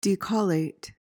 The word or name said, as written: decollate